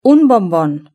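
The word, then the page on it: un bombon: European Spanish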